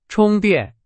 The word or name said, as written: 充电